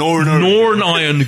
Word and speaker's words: Norn Iron: Or yeah, Norn Iron Gunnar, as in the pronunciation. Norn Iron. N-O-R-N, Iron Gunnar.